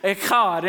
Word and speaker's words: echad: You have to have a little bit of phlegm in your throat to say it correctly. It's echad.